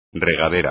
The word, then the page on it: regadera: espagnol